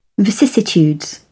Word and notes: vicissitudes: British English

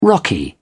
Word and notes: rocky: British English

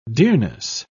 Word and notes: dearness: British English

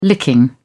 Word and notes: licking: British English

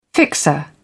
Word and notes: fixer: British English

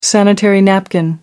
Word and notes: sanitary napkin: British English

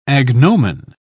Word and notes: agnomen: British English

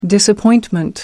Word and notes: disappointment: American English

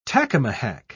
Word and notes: tacamahac: British English

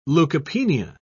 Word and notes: leucopenia: British English

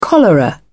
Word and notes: cholera: British English

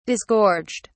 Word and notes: disgorged: British English

